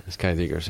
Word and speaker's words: Skydiggers: Skydiggers.